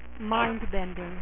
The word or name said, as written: Doctor Mindbender